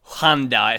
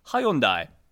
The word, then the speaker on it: Hyundai: Yeah, oh, by the way, it's not Hyundai. It's not Hyundai. It's Hyundai.